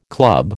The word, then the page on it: club: American English